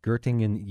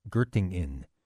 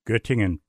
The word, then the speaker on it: Göttingen: He has held professorships at Göttingen University in... I'm saying that wrong. Göttingen. – Göttingen, perfectly pronounced.